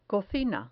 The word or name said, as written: cocina